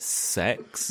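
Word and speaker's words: CeX: I'm sorry, but half of my note is they pronounce it sex. – Yeah, like, that's how. That's how you say it. Sex. Because computer exchange is sex.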